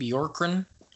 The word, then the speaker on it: Bjorkgren: I finally learned how to pronounce his last name. When I first heard it, I was like, how on earth? It's like Bjorgren or something like that. So I was like, how on earth do you pronounce it?